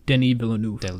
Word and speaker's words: Denis Villeneuve: Denis Villeneuve. – Denis, like Dennis, but French. Dennis without saying the S.